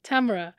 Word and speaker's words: Tamra: Actually, my grandmother named me Tamra. Rhymes with camera. Okay. So all around the world, Muslims are calling me things like tomorrow and Tamara and things like this. But I'm working on helping them pronounce my name correctly. It's Tamra. Yeah.